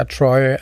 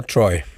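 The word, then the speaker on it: Troyes: Okay, der ligger en by, der hedder Troy. Og det skal selvfølgelig udtales på fransk.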